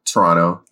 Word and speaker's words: Toronto: The fact that it's set in Toronto, or Toronto, as I think people in Toronto pronounce it, Toronto. You're not supposed to pronounce that second T.